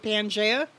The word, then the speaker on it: Pangea: So, Pangea. Am I saying that right? Is it Pangea? – Yes, you are.